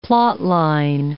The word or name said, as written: plotline